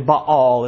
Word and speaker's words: Baal: The first one was Baal, is the way we say it. It's probably Baal is the correct pronunciation.